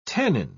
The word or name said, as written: tannin